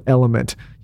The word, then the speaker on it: LMNT: And one of our favorite supplements, one of my favorite supplements right now, is a product called Element. You've probably seen it around. It's spelled L-M-N-T, very creative, Element.